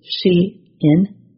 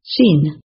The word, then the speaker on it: Shein: Online fast fashion business Shein is Shein, not Sheen.